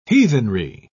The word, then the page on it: heathenry: British English